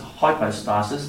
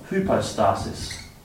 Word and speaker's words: hypostasis: The Greek word is the word, you can pronounce it two ways. Sometimes it's hypostasis, but hypostasis. Hypostasis.